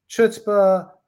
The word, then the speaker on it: chutzpah: So if we think about it in terms of chutzpah, and by the way, it's pronounced multiple ways, chutzpah, the C-H is depending on where you are, it can, CH in the word character sounds like a hard K.